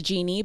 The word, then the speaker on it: Djini: So it's pronounced Genie, but spelled DJINI Productions.